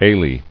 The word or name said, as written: Alae